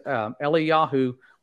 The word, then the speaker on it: Eliyahu: And when you look at their names, Eliyahu was how you would have pronounced Elijah in the Hebrew pronunciations, and Elisha would have just been Elisha.